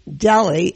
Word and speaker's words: Delhi: Next we have Chitra Agrawal talking about her business, her company called Brooklyn Deli, and Deli is spelt D-E-L-H-I, so you could got a guess at what kind of products she has, and it's a wonderful line of products.